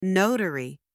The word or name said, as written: notary